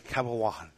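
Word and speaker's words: Cabaiguan: You got to pronounce the G, huh? Can't you say Cabaguan? – All right, Cabaguan. – Thank you. – It's like a Juan.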